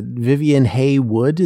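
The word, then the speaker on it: Vivienne Haigh-Wood: I know he had one unhappy marriage to Vivian Haywood. Is that how you pronounce that? – Yes.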